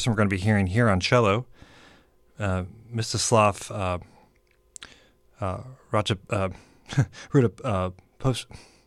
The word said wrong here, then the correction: Rostropovich: Man, I wrote it out so I would pronounce it right, but if I just read it, I would have had it right anyway. Mstislav Rostropovich.